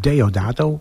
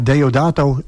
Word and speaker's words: Deodato: Hoe zeg ik het goed weer? – Deodato. – Ja, Deodato.